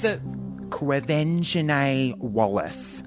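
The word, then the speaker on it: Quevenzhané Wallis: It is Quevenzhané Wellis.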